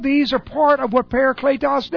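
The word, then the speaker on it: Parakletos (παράκλητος): Parakletos. That's para. Kletos. P A R A K L long E T O S. Parakletos.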